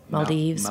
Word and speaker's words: Maldives: Is that, am I saying it correctly? Maldives. – Yeah. It's the destination.